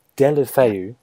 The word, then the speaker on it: Deulofeu: Is Gerard Deulofeu, is that how you say his name? – Deulofeu.